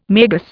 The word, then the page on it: magus: Indian